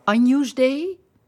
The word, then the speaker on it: Agnus Dei: Dan gaan we naar de laatste ronde met het Agnus Dei. Zeg ik dat goed? – Zeker. – Aileen, Agnus Dei en het Dona Nobis Pacem.